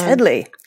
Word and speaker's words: Hedley: That's Hedley. You said it correctly.